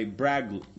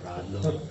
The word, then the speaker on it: Bradlaugh: laughs> The other kind was taught by Bradlaugh. Like I said, they got to work on their names here. – It's pronounced Bradlaugh.